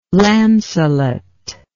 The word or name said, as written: Launcelot